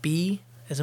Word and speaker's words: Bae: It's a Korean last name, and uh, Koreans pronounce it something like ba, almost like a sheep sound, you know, ba. But in English, uh, B A E would be pronounced bay, and that's always the way that we pronounced it.